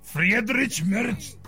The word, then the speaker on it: Friedrich Merz: Friedrich Merz.